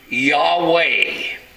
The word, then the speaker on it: Yahweh: Y-H-V-H. And that is pronounced Yahweh. Y-H-V-H.